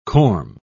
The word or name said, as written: corm